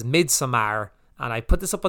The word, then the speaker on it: Midsommar: I love how you really emphasize that it ends with an AR. Midsommar.